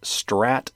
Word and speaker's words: STRRAT: It says, Microsoft on Thursday warned of a massive email campaign that's pushing a Java-based Strat malware, and that's spelled S-T-R-R-A-T. That's short for something. RAT is a remote access Trojan, which is the type of malware. Anyway, it's called Strat, to steal confidential data from infected systems while disguising itself as a ransomware infection.